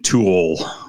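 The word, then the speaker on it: To Øl: I mean, I'm white guy, white American pronunciation, Tool.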